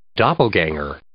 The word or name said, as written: doppelgänger